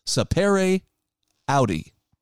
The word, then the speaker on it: sapere aude: It's a Latin phrase, sapere aude." I think I'm saying that correctly. Hang on a second. I'm going to double check my pronunciation. – Sapere aude. – Sapere aude. That's it.